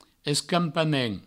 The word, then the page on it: escampament: escampamen; provençal